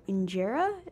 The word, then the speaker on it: injera: So every site that I visited was talking about injera, is that how you pronounce it? – Oh yeah.